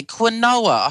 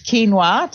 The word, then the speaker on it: quinoa: Now, I've got another one here, quinoa. I hope I've pronounced that right. I've heard that this is a superfood. What actually is quinoa? – Okay, it's quinoa to start with.